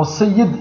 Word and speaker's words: Sayyid: Même le mot Sayyid, ils le prononcent mal. Ils disent Sidi Fulan. Et Sidi Fulan, c'est Sayyid.